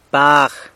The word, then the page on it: bag: San Lucas Quiaviní